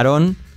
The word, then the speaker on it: don't: Fíjate, I don't, sin la T, pero T como resuelve, I don't feel, no me fío, feel, la L casi no se dice like.